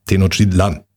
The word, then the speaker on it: Tenochtitlan: Der Apostroph ist auf, oder der, die Betonung liegt auf L. Auf dem letzten A, Tenochtitlan.